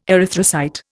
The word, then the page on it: erythrocyte: Indian